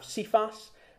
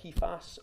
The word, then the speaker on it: Cephas: We have Cephas, or Kephas, or Cephas, depending how you want to pronounce it.